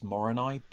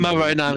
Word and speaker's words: Moroni: I think the pronunciation is Moroni, but yeah. Annoying. – Moroni was correct.